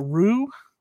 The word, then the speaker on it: rue: How do you say it? Rue? Rude? – Rue. It's like R-U-E.